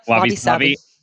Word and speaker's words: wabi-sabi: You wrote about wabi-sabi. Is that pronounced right? – Yep, wabi-sabi.